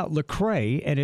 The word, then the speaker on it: Lecrae: Yeah, Lecrae, and it's spelled L-E-C-R-A-E. Lecrae, L-E-C-R-A-E. He's a young Christian rapper.